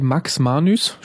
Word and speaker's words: Max Manus: Ja, dieser wohlgenannte Max Manus, spricht man ihn so aus? – Ja.